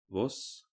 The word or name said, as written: Voss